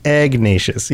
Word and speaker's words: Ignatius: It's not a Q, it's a G. – Ignatius. – It's Ignatius.